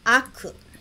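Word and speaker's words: Aku (悪): Esse warui tem outra pronúncia. Aku. Aku.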